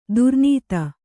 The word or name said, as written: ದುರ್ನಾತ